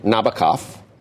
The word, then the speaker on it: Nabokov: Well, anyway, so here just real quickly, Frenchmen say Nabokov.